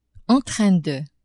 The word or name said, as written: en train de